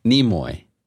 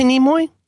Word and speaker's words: Nimoy: Nimoy. – You say Nimoy? – Yeah.